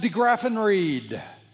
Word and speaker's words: DeGraffenried: Small D-E, space, capital G-R-A-F-F-E-N-R-I-E-D. It's pronounced DeGraffenried.